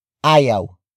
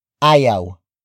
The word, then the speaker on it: ayaw: In this case, we add this word to the sentence. Ayaw. Ayaw. Ayaw. Ayaw is spelled as A-Y-A-W.